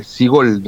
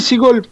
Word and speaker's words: Szczygol: Sí, los otros son todos como consonantes, ¿viste? Así que Sigol nomás directamente.